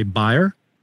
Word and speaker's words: Bayer: I think we pronounce it Bayer, but you would say Bayer.